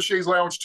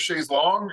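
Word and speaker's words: Chaise Longue: Although I pronounce it Shay's Lounge too, but in the song it's Shay's Long.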